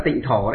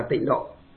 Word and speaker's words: tịnh độ: Trong chữ Hán nó là tịnh thổ đấy. Nhưng mà nhà Phật chúng ta đọc là tịnh độ.